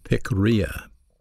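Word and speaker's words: pikria (πικρία): The word bitterness is a Greek word pronounced pikria. It's spelled P-I-K-R-I-A.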